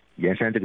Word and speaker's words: 铅山: 对对对。这是古汉语里的一个读音，古汉语里的一个读音。其实这个字只有在盐山这个地名的时候才读盐，其实是当地的这个古汉语，当地的这个方言啊，一直读盐。